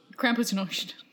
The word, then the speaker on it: Krampusnacht: Krampusnacht. – Krampusnacht. – There it is. – That's right way. That's better. – That sounds right.